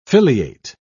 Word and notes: filiate: British English

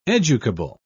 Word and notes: educable: British English

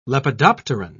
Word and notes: lepidopteran: British English

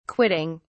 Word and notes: quitting: British English

